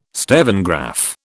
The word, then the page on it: stevengraph: British English